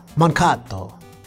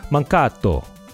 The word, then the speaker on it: Mankato: I believe the correct pronunciation is, the proper way to say it is Mankato. – All right, yeah. – The native way of Mankato. – That's the proper way they say it over in that part of the United States.